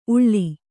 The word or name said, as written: ಊಳ್